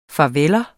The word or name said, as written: farveller